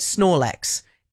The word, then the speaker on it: Snorlax: Yeah, Snorlax.